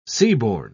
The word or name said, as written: seaborne